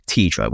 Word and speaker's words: T1DRA: But first, we're very excited to discuss a brand new world-first screening program for adults at risk of type 1 diabetes called TIDRA, which is spelled T1DRA, and this stands for the Type 1 Diabetes Risk in Adults Study.